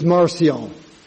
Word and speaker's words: Marcion: Okay, so the first man I'm talking about, the first brother, is Marcion. M-A-R-C-I-O-N.